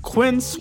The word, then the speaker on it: Quince: Hey, Today Explained's support today comes from Quince, which rhymes with since but is spelt with a Q-U.